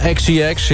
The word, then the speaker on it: XCX: Nummer 2, Charlie XCX. Zeg ik dat goed? – Ja, XCX.